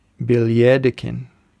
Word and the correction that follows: Billjakten: Is that pretty, am I getting close? – Oh, yeah. Billjakten. – Billjakten. Billjakten. Yeah, your pronunciation's pretty good, so well done.